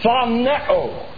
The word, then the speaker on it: phaneo: If you were to transliterate it into English, it'd be F-T-H-A-N-E with a long O. Phaneo. Put the together is not easy.